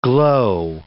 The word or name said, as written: glow